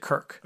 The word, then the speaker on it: Kirk: I pronounce it Kirk. – Okay, good. – K-I-R-K.